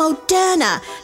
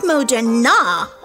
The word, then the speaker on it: Moderna: It's Pfizer and Moderna, not Moderna.